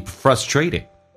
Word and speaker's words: frustrated: If you're dissatisfied with some area of your life right now, OK, 下面一句 Instead of being frustrated, 取而代之的来代替 frustrated, 感觉到很受挫。那么 being frustrated 这个单词美式发音或许有点难听懂， frustrated 发着 dr, 后面的 t 也发成 d 叫 frustrated。